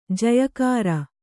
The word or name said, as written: ಜಯಕಾರ